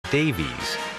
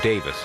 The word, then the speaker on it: Davies: Davies, even though Davis is the common British pronunciation.